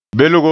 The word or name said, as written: belgo